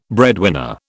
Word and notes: breadwinner: British English

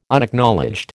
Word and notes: Unacknowledged: American English

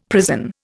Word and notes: prison: Indian

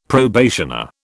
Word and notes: probationer: British English

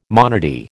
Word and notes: monody: American English

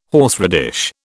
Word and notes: horseradish: British English

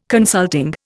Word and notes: consulting: Indian

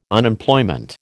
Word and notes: Unemployment: American English

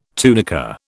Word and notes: tunica: British English

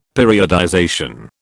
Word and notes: periodization: British English

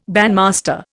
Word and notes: bandmaster: Indian

